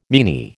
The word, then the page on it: meanie: American English